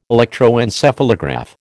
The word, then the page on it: electroencephalograph: American English